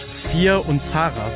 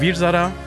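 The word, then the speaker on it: Veer-Zaara: Und zwar zu Deutsch Vier und Zara. So haben die das wirklich immer ausgesprochen. Im Indischen oder im, so wie ich es gelernt habe, heißt der Virzada.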